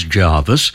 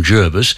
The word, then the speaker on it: Jervis: The English pronunciation is Jarvis. Mm. When the Navy set up a major base there. They asked for the pronunciation to become Jervis because I gather that it was named after a British admiral and he pronounced his name Jervis, which the Navy knew and therefore they wanted his pronunciation to apply.